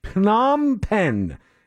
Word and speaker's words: Phnom Penh: It's P-H-N-O-M. Phnom Penh is how you say it.